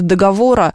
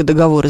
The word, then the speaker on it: договоры: Ну, во-первых, зависит от ударения, потому что договора, если вы имеете в виду договора, то это будет неправильная форма, значит, только договоры, значит, это родительный падеж.